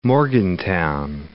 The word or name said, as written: Morgantown